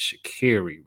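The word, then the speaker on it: Sha'Carri: It's not Shikari, it's Shikari Richardson.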